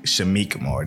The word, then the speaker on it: Shameik Moore: That is a movie that was directed by the RZA, and I want to get, I think, Shaheem Moore. I want to get his name right. He also was, I think he got his first, like, Shameik Moore.